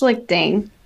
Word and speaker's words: Schlichting: It's it's pronounced Schlichting. It's German.